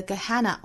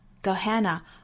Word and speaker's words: Gehenna: 我们来看前半句 Down to Gehenna or up to the throne, 这里面的 Gehenna, 我们注意有两种发音的方法、啊、一个是第一个音节读成 tra sound 的 g, Gehenna, Gehenna, 或者是把前面音节读成 i 的元音 Gehenna, Gehenna, 这两种读法都对。